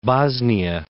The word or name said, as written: Bosnia